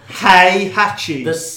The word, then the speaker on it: Heihachi: He just constantly mispronounces Heihachi. – Yes, Hechoi, whatever he's called, the big guy is going down. – Big H. – You're adding extra letters.